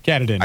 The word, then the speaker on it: Katahdin: Katahdin. – Katahdin. – I think that's how that's pronounced. – Yeah, that's it.